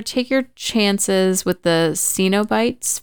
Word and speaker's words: Cenobites: So first of all, it's pronounced Cenobites.